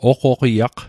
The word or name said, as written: oqoqiaq